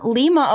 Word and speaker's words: Lima: Next up, we have Robin, correction, Lima, Ohio. Oh, shit. Oh, it's pronounced with a long I, as in lima bean, not as in Lima, Peru.